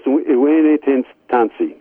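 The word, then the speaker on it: Iwene Tansi: And it's from somebody out in Nigeria, Blessed Cyprian Michael, I'm going to pronounce this wrong, I'm going to try it, Iwane Tansi. So let me try it. Pretty close. Iwane Tansi, yeah.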